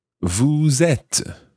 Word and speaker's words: vous êtes: S-O-M-M-E-S, nous sommes. Then the vous form, the you plural or the you formal, vous êtes. So that's E circumflex, T-E-S, vous êtes. And notice that when you follow vous with êtes, you pronounce the S of vous, vous êtes.